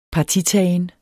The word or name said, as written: partitagen